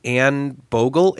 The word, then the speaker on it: Anne Bogel: Well, I just want to introduce to you Anne Bogel. Is that how you say it? It is. You did great.